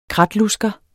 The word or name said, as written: kratlusker